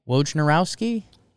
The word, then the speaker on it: Wojnarowski: Wojnarowski? – Yeah.